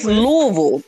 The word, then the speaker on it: Louisville: It's Louisville. Like, don't, that's too much. You know what? We're not going to do this. That's a struggle to pronounce all them letters. It's Louisville.